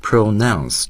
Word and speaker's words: pronounce: Pronounce 注意它的重音位置是 nounce，而 pronunciation 它的重音是有区别的，注意动词名词形式它的重音有变化。